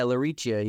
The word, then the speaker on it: LaRiccia: Yeah, LaRiccia, you got me.